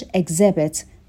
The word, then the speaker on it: exhibit: توجه کنید به تلفظش exhibit. در واقع اون اچی که داره تلفظ نمیشه.